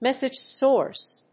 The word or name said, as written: message source